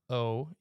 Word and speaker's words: Gloucester: Gloucester. – Yeah, Gloucester.